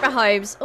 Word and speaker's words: Mahomes: He tried to find Patrick Mahomes. Is that how you say it? You know NFL better than me. – So Patrick Mahomes.